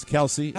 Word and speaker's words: Kelce: Kelce. – It's Kelce.